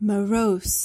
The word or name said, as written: morose